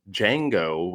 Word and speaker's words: Django: Um, we're here to talk about a 1966 classic by the name of Django with a D. The D is silent.